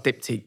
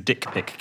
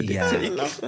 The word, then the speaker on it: Diptyque: I've got Diptyque candles now. – Oh, wow. – Which, for a while, he was pronouncing as Dick Pick candles.